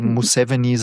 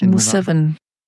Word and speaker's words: Museveni: Museveni wird er auch manchmal ausgesprochen, genau, aber so Museveni sagen die eigentlich. – Museveni.